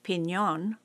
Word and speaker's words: piñón: Officially declared the state tree in 1948, the name of this drought-hardy native can be spelled P-I-N-Y-O-N, P-I-N-I-O-N, or P-I-N-O-N. But any way you spell it, and whether you add the Spanish pronunciation of piñón, which means nut pine, the tree can be a dandy addition to your landscape, especially if you're trying your hand at a low-water, low-maintenance layout.